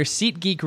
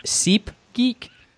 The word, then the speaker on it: SeatGeek: In your SeatGeek read, you started things off with SeepGeek.